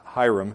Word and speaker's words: Huram: Then Solomon sent word to Huram, the king of Tyre. That can also be pronounced Hiram. In fact, I'm going to, I'm going to pronounce it Hiram.